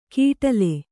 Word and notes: ಕೀಟಲೆ: Kannada

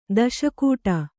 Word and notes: ದಾಸಗೌಡ: dāsa gauḍa

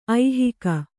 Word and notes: ಐಹಿಕ: aihika

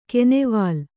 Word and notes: ಕೆನೆವಾಲ್: kenevāl

